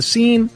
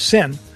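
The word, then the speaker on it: Seine: You know, people getting sick swimming in the scene. We say scene, but it's actually pronounced sin.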